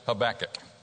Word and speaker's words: Habakkuk: I've heard this man's name pronounced a lot of different ways, but we're going to pronounce it Habakkuk.